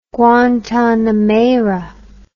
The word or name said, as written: Guantanamera